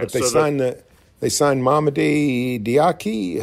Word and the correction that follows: Diakite: How do you say his name? – Diakite. Yep.